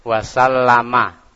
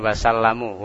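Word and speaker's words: Wasallama (وَسَلَّمَ): Wasallama, bukan wasallamu, wasallama. Sallallahu alaihi wasallama Rasulullahi, Rasulullahi. Muhammad sallallahu alaihi wasallama Rasulullahi.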